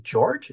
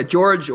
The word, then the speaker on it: Georg: And I loved how in the epilogue, now there's a character named, I hope I'm pronouncing his name, George, is that correct? – Yeah, George or Georg would be the German way to say it.